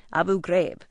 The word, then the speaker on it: Abu Ghraib: And yes, that's it, Abu Ghraib.